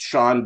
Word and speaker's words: Chaon: Yeah, and I just want to let your listeners know that it's pronounced Sean, but it's spelled C-H-A-O-N.